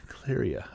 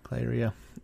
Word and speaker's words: Cleyra: I'm not sure if you call it a unique monster, but my favorite were the Black Mages, who appear in Clyria. I believe that's how you say it. Clyria. C-L-E-Y-R-A.